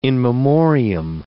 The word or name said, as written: in memoriam